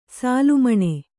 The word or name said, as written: ಸೂಲಮನೆ